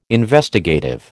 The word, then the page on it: Investigative: American English